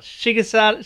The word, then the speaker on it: Shigesato: Shigesato.